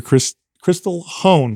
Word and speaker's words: Krystal Hohn: All right, Dr. Becky Campbell, Dr. Krystal Hohn. Mm-hmm. I said it right. – Yep, you did.